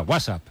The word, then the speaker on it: WhatsApp: No, peor aún, con acento en la primera. WhatsApp, ¿no?